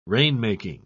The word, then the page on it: rainmaking: British English